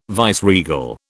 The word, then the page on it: viceregal: British English